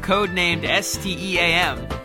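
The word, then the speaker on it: Code Name: S.T.E.A.M.: First up this week, we have a hands-on preview of Codenamed STEAM.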